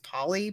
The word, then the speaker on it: Polley: And I believe her last name is pronounced Polly, P-O-L-L-E-Y.